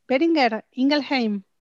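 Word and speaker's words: Boehringer Ingelheim: Lo primero que te tengo que preguntar es, ¿pronuncié bien Beringer Ingelheim? – Muy bien, la verdad que muy bien.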